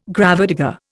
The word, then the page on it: gravedigger: Indian